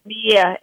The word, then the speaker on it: anosmia: Anosia. – Anosmia. A-N-O-S-M-I-E. Anosmia. – Okay, I pronounced it wrong. Sorry.